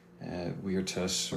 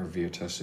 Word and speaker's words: virtus: So virtue is a Latin word, virtus or virtus if you're using a later pronunciation.